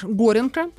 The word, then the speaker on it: Горенко: поскольку а, отец Андрей Антонович Горенко, там ударение на первый слог, считал, что писать стихи — это унизительное занятие.